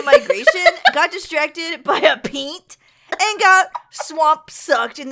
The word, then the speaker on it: peent: is a short, buzzy sound known as peent. – No. – It's spelled P-E-E-N-T.